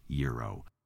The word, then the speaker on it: gyro: Firehouse Subs surveyed Americans to determine how the masses pronounce the word that's spelled G-Y-R-O and settle this long-standing food debate in celebration of its newest menu item, the Chicken Euro Sub. That's right, it should be pronounced Euro. But the survey of 1,000 Americans, conducted in conjunction with Wakefield Research, uncovered that 23% pronounce it Gyro, followed closely by 19% who pronounce it gyro.